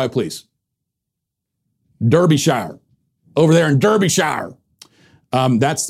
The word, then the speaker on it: Derbyshire: To add the correct sinister touch and not distract us with the comical pronunciation Derbyshire, please note that the name of this county is pronounced Derbyshire, with the emphasis on the first syllable.